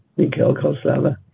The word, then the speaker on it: Mikel Calzada: So his name is Mikel with a Q, Calzada with a C or whatever that is on the second C. It's a soft C. Mikel Calzada.